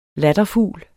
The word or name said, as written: latterfugl